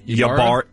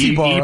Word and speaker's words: Ybarra: So the guy's name, I'm guessing it's spelled Y-B-A-R-R-A. – Yeah. – Is that Yabara? – It's Ibarra. – Ibarra? Yeah. I've heard it Ibarra before.